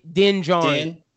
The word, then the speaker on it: Din Djarin: Yeah, Din Djarin.